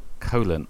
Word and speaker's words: Coughlan: Coughlan, I think, is like cough and lan.